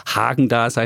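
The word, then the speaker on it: Häagen-Dazs: Und dann habe ich neulich mal im Sonderangebot, weil ich hier der Schnäppchenkäufer bin, hatte ich Hägendas oder Hagendas, heißt es, so wird das ausgesprochen, Vanille da.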